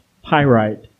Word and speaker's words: pyrite: He took his magnifying glass and looked it over and promptly pronounced pyrite.